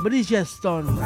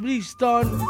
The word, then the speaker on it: Bridgestone: O Bridgestone. ¿Cómo se pronuncia? Bridgestone. Ahí está, Cubiertas Brixton.